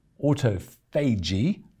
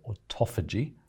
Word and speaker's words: autophagy: It's called autophagy, means self-eating, or the Americans call it autophagy.